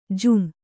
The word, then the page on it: ಜುಂ: jum